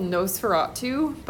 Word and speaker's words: NOS4A2: It's spelled kind of funny, so if you go into our catalog and you're looking for it, it's NOS4A2. So NOS4A2. If you think of it like a license plate, that's how it's set in the book.